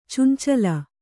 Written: ಚೆಂಚಲಿ

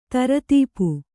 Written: ದಾರಿದಪ್ಪು

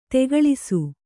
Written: ತಗುಳಿಸು

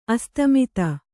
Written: ಆಸ್ತಿಮಿತ

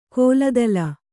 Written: ಕೋಲದಲ

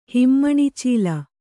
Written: ಹಿಮ್ಮಣ್ಣಿಚೀಲ

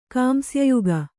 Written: ಕಾಂಸ್ಯಯುಗ

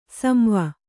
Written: ಸಂವ

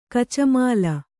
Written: ಕಚಮಾಲ

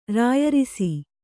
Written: ರಾಯರಿಸಿ

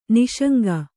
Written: ನಿಃಶಂಕ

